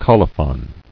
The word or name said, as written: Colophon